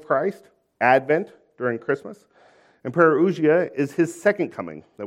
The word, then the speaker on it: parousia: It's the word parousia. If you're taking notes, it's spelled P-A-R-O-U-S-I-A, parousia.